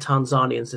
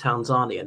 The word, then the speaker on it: Tanzania: I met Tanzanians and it's pronounced Tanzania, not Tanzania.